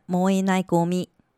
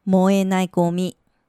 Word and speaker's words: 燃えないゴミ: 那么不可燃垃圾念成燃えないゴミ，燃えないゴミ，燃えないゴミ。